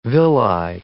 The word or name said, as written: villi